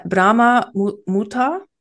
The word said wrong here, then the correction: Brahma Muhurta: Brahma Muhurta.